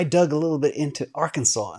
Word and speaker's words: Arkansas: So, Northwest Arkansas, or I always say that wrong, Arkansas, is providing remote workers with an incentive in the form of $10,000 in Bitcoin if you relocate to the region.